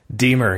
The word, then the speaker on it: Diemer: I think it's Deemer or Dimer. I know the I and the E, that always confuses me where they go in the word and how it's pronounced. So from Wrigleyville Nation, it's Jeremy. Tell me. – Deemer. You had it right the first time.